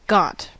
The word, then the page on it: got: /ˈɡɒt/; /ˈɡɑt/